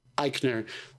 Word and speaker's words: Eichner: I, I, I don't know if it's because of things like you're talking about, where like Billy Eisner was basically Eisner. How have you say his name? I'm sorry. yeah. – Eichner.